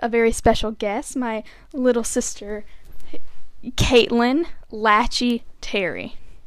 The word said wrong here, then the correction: Lachey: Hello, and it is pronounced Lachey. – Her name is actually Cadence Lachey Terry.